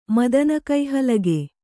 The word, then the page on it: ಮದನಕೈಹಲಗೆ: madana kai halage